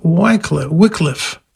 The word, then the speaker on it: Wycliffe: Interestingly enough, Wycliffe, that's how you pronounce the name properly, he was one of the proto-reformers a century or two before Martin Luther.